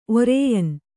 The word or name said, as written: ಒರೇಯನ್